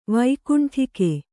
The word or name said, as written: ವೈಕುಂಠಿಕೆ